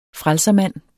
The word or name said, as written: FRELSERMANDEN